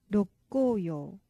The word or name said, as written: rokkoyou